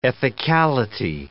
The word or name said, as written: ethicality